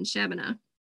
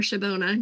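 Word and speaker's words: Shabbona: Yeah, she's gonna be at the farmer's market, I think, this weekend in Shabana. Or Shabona, depending on how you want to pronounce it, what part of Illinois you're from.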